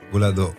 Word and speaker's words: Boulado: Zeg ik dat goed eigenlijk? Boulado. – Boulado. Ja, Boulado. Goed gezegd.